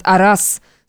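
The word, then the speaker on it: арас (ارس): Аракс с персой произносят «арас», следовательно, корень здесь тот же «рас».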